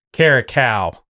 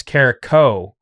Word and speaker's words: Carrico: So we've been friends for a while, and I'm always saying Eric Caracal, but it's hard for me to pronounce Carrico. Right? So like I have to tell myself over and over again to say Carrico and not Cal.